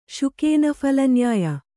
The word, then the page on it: ಶುಕೇನಫಲನ್ಯಾಯ: śuka phala nyāya